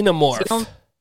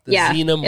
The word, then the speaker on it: Xenomorph: The Xenomorph. – Xenomorph? – Yes. – Xenomorph.